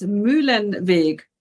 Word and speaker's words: Muehlenweg: My last name is Muehlenweg.